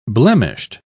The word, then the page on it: blemished: British English